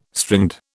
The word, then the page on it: stringed: British English